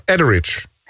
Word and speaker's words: Etheridge: Melissa Etheridge. Zeg ik het goed? – Etheridge.